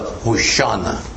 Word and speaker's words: Hoshana: This song was called the Hosanna, or more correctly pronounced in Hebrew, the Hoshana.